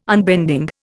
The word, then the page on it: unbending: Indian